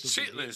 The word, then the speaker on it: chitterlings: And it's chitlins. – Shit. – Don't pronounce the ER, you motherfucker.